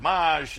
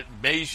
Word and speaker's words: Mage: Before we let you go, when you sat down, I was asking you the correct pronunciation, Mage, because I said I've heard Maj and Maj, but Mage it is.